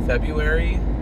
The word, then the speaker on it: February: February, with a silent R, comes from, I think...